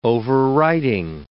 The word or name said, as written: overwriting